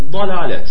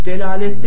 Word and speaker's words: dalalet: Bunun zıttı da dalalet. Delalet değil, o bazı yerlerde yanlış geçiyor. Kalın olarak okunuyor, datla okunuyor. Dalalet.